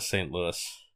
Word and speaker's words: St. Louis: Do you pronounce it St. Louis or St. Louis? – I'd always go with St. Louis. – Good, because so would I, and so would people I know from St. Louis.